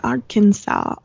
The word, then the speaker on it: Arkansas: Arkansas. I wonder why that S is silent, but whatever.